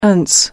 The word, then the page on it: ance: American English